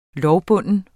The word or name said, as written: lovbunden